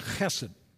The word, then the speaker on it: chesed: Now, the Hebrew word, and forgive me for a moment here for talking about a Hebrew word, but is a word that is pronounced chesed.